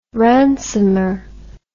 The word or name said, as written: ransomer